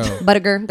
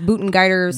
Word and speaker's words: Buttigieg: Buttigieg, that's not really how you say it. It's Buttigieg.